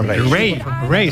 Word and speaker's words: Raye: Ray. – Se escribe Raye.